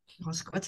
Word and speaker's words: Roscoat: Roscoat, ça s'écrit exactement comme ça se prononce, R-O-S-C-O-A-T.